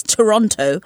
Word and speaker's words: Toronto: I know that's not the correct pronunciation, but I am British, and we, in fact, pronounce all the T, so it's Toronto.